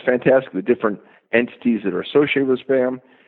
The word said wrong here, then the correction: SBAM: We should correct you. It's pronounced SBAM because SPAM is something quite different.